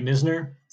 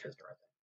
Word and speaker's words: Knizner: I mean, Herrera, I think, has gotten sporadic plate appearances this year, but they seem to like Andrew Knizner. I don't know why. Or Kisner. It's spelled like Knizner, but it's pronounced Kisner.